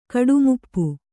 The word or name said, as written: ಕಡುಮುಪ್ಪು